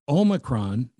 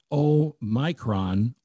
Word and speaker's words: Omicron: The correct pronunciation, according to many scholarly experts that don't include me, is Omicron, not Omicron or Omicron. It's O H M Y cron, but the emphasis on the O.